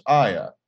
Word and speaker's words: Halla: The other thing that's interesting about our specific spelling is that in Spanish, it's actually pronounced haya, and haya means to find or discover.